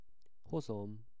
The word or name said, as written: hozom